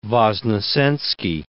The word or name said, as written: Voznesensky